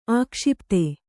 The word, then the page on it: ಆಕ್ಷೇಪಿತ: ākṣēpita